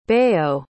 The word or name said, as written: bayode